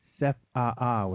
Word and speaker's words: CEFAA: And that's how you say it, the A. You say both A's, CEFAA, which sounds kind of cool.